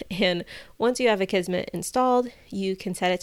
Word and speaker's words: Akismet: And the next blogging related automation is to take care of spam comments with a plugin called Akismet. Akismet, I might be pronouncing this wrong, it's A K I S M E T, is a low cost plugin.